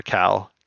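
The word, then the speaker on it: Kal: Neo underscore Cal, Cal with a K at Twitter.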